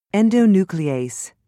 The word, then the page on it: endonuclease: British English